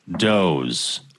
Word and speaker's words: Dawes: Looking forward to the Da Wez podcast. – I think that's pronounced Doze. – Oh, is it? – I think so. – Okay. I think it's pronounced Doze.